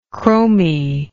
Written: chrominess

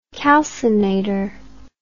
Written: calcinator

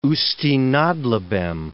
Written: Usti nad Labem

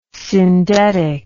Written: syndetic

